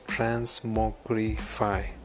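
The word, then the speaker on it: TransmogrifAI: The next one in my list is Transmogrif AI. Uh, this is pronounced as Transmogrify.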